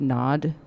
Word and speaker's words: Gnod: And then we're going to do a bunch of dates with Nod, which is spelled G-N-O-D, mm-hmm. – in case I'm not enunciating.